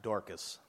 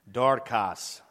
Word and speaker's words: Dorcas: Not Dorcas. That's what I was calling her last week. I'm a moron. Dorcas. That's how it's pronounced.